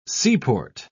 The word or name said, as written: seaport